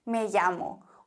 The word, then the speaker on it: llamo: Me llamo. Und Doppel-L wird im Spanischen wie ein J ausgesprochen, zumindest im Castellano. In Argentinien würde man sagen, me chamo. Aber in Spanien sagen wir me llamo.